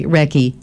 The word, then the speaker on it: Recce: Recky. That's spelled R-E-C-C-I, right? – R-E-C-C-E. – R-E-C-C-E, sorry, Recky.